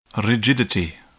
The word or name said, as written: rigidity